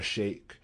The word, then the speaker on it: Sheikh: Kabida is spelled Troy as in the city, Kabida, C-A-B-I-D-A. Gabriel P. Jones is his handle. There's Zahara Sheikh, at Z-A-H-R-A-H. Sheikh is spelt S-H-E-I-K-H, as in teacher, not shook.